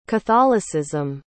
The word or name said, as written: catholicism